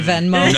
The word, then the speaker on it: Venmo: It's not German. It's not that W. Venmo, but we say Venmo. – No, okay. It's V-E-N-M-O.